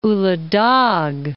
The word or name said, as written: Ulu Dag